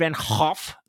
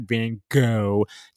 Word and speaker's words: Van Gogh: And it's not Van Gogh. It's not like go dog go. It's Van Hoff. You got a Hoff.